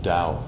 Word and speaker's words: Tao: Now the way in Chinese, the term for that is Dao, T-A-O, it's pronounced Dao.